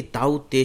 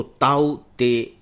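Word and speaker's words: Tauktae: और ताऊ, चाचा ताऊ याद रखना भी बहुत आसान है, और चूंकि ये नाम म्यांमार ने दिया है तो मुझे लगता है कि ताउते शायद ज्यादा सही होगा, क्योंकि इधर जो नॉर्थ ईस्ट के हमारा एरिया है या पूरा का पूरा ये जो दक्षिण पूर्वी एशिया है इसमें ट का उच्चारण ज्यादातर त ही होता है, तो ताउते एक सही नाम है। टी ए यू के टी ए ई, इसमें शायद के साइलेंट है।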